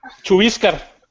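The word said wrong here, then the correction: Chuvíscar: Chubíscar, güey, con la she. – Chubíscar. – Chubíscar. – Acento en la I. – Perdón, C-H-U. – V. – V. – I. – I-S-C-A-R.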